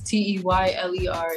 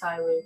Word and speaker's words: Teyler: It's T E Y L E R. It's Teyler. It's not Tyler.